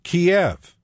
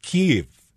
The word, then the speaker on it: Kyiv: So I went looking for the answer, and it turns out in Russian, the city's name is Kiev. In Ukrainian, it's always been pronounced Kyiv, and the rule is Ukrainians call their city Kyiv, so reporters call the city Kyiv.